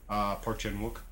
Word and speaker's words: Park Chan-wook: Park Chan-wook.